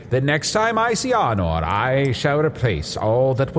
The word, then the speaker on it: Arnor: So when you see Arnor, right? Arnor mm-hmm. rhymes with door.